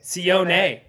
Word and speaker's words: Sione: Sione. – Oh, that is not a silent E.